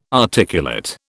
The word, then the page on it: articulate: British English